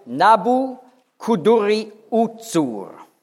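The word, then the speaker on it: Nabû-kudurri-uṣur: auf Babylonisch, auf Akkadisch ausgesprochen, lautet Nabu-Kuduri-Uzur.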